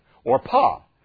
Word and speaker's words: Orpah: Orpah is actually how it's pronounced.